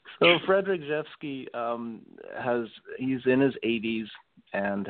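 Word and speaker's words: Rzewski: It's pronounced Jeffsky. It's a... – Seriously? Oh, wow. – Yeah. It's a Polish... I know, right? It's a Polish name. And so there's a lot of consonants that don't get pronounced.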